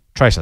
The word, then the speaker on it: TraCR: And I came across something that seemed new on CommSec called a Tracer, T-R-A-C-R. Or is it Tracker? How do they pronounce it? Do you know? Tracer. There we go.